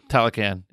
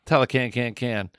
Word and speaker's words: Talokan: The moving through, I can't pronounce it, Tamalika? – Talokan. – Yeah. Talokan.